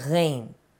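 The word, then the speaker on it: Ghain (غين): Yeah, and then after that, there's a Ghain. – Ah. – Which is pronounced like gh. Like you see, if you try too hard, it's gonna go more to the gh sound.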